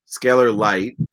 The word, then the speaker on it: Scalar Light: There he is, the researcher at Scalar Light, and I hope I pronounced that correctly.